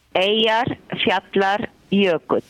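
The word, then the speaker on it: Eyjafjallajökull: Piero, però non date la colpa al vulcano, anzi il nostro vulcano che si chiama Eyjar Fjallar Jökull. – Oh, se vogliamo dirlo bene in lingua precisa.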